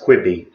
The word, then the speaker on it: Quibi: And it's pronounced Quibi.